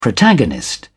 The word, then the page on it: protagonist: British English